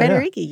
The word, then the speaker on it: Federighi: Yeah, Federighi, yeah.